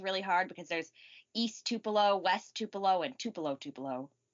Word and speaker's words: Tupelo: and I can't even pronounce it. It's Tupelo? – Tupelo, yeah.